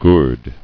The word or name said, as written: Gourde